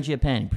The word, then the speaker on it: Mangiapane: Now, Andrew Mangiapane, is that how you say it? – Mangiapane, pretty good.